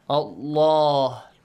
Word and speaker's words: Allah: يَعْلَمْهُ اللَّهُ the word Allah, the lam in the word Allah must be pronounced heavily. Allah, not Allah, no. Yes. So, يَعْلَمْهُ اللَّهُ because you have a dhamma sound before. If you have a dhamma sound, a fatha sound before the word Allah, the letter lam is pronounced with a heavy sound.